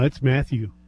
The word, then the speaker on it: Mathieu: No, it's Mathieu.